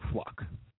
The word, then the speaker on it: Fluck: It's a fluke to me, but I have to say her name properly. Sandra Fluck.